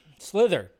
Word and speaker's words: Slither: Sliver, yeah. Slither, not Sliver.